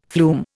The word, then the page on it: flume: Indian